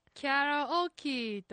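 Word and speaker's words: karaoke: キャラオーキーと発音しますが、キャラオーキー？ – キャラオーキーになっちゃうんですね。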